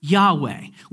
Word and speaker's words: Yahweh: Now in English, we'll pronounce this as Yahweh. We kind of throw in some letters to kind of fill it out. We think that's probably the proper pronunciation of it. We're not 100% sure, but it's close enough, okay?